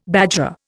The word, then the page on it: bajra: Indian